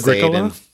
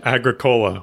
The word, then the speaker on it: Agricola: Is this pronounced Agricola? – Yeah. – Okay. – Yeah. – I pronounce it Agricola, which makes me sound. It makes it sound like a great new flavor of Coke.